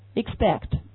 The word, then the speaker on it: expect: Expect 好，跟我说 expect 很明显这个 P 也会读成什么 b expect 好，那这个词我们来看一下。